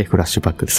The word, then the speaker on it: フラッシュバック: そして、昨年も、赤岩の残像と書いてフラッシュバックで再開と。